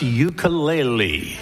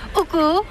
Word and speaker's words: ukulele: pronounce ukulele. – Ukulele. – Ukulele. – Uku. – Uku. – Lele. – Lele. – You got it.